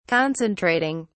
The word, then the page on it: concentrating: British English